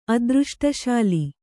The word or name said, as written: ಅದೃಷ್ಟಶಾಲಿ